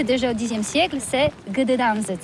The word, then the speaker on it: Gdańsk: Oui, c'est pas très facile pour des étrangers, mais remarque bien que la langue polonaise, c'est la langue où on prononce chaque lettre qu'on écrit. C'est pourquoi on dit Gdańsk. – Gdańsk.